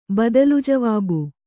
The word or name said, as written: ಬದಲುಜವಾಬು